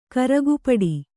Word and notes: ಕರಗಪಡಿ: karagapaḍi

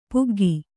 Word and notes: ಪಕ್ಕಾಗು: pakkāgu

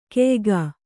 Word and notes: ಕಯಿಕೆ: kayike

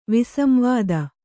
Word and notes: ವಿಸಂವಾದ: visamvāda

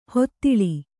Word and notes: ಹೂದಳ: hū daḷa; Kannada

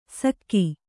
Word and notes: ಸಕ್ಕಿ: Kannada

